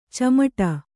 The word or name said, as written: ಚಿಮುಟು